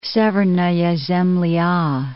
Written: Severnaya Zemlya

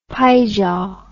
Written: pijaw

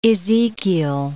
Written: Ezechiel

